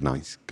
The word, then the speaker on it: gneiss: I think it's pronounced gneiss, gneiss, G-E-G-N-E-I-S-S.